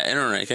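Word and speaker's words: Ehrenreich: So, I can't remember how he pronounced his name. It's Alden Aaron Reich. – Yeah, Aaron Reich. Yeah. – Aaron Reich. – That's correct.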